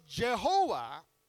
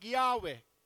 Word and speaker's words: Jehovah: So Jehovah is the covenant name of God. In the original Hebrew, they would probably pronounce it like Yahweh. And we more commonly use Jehovah, same name.